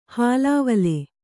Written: ಹಳವಳ

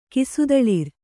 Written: ಕಿಸುದಳಿರ್

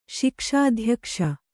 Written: ಶಿಕ್ಷಾಧ್ಯಕ್ಷ

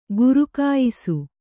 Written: ಘರಖಾಯಿಸು

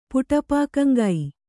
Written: ಪುಟಪಾಕಂಗೈ

